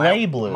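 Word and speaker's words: BlazBlue: It's actually pronounced Blay Blue.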